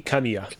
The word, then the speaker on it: Kamiya: So Kamei, how do you say his name? I always say it wrong. – It is Hideki Kamiya.